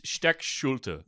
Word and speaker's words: Steckschulte: It's Steck Schulte. – Steck Schulte. – Steck Schulte. But Americanized in some stupid fucking way. He's just like, no. We pronounce it Steckschulte.